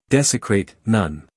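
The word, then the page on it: desecrate: uk ˈdesɪkreɪt; en-us ˈdesɪkreɪt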